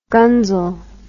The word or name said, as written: gunzel